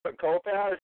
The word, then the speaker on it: Kopacz: I hope I pronounced your last name right. Kopax? – Yep, Kopax.